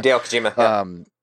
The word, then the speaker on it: Hideo Kojima: It's a conspiracy theory-esque about Hideo, can I ever pronounce it? Hideo Kojima? – Hideo Kojima, yeah.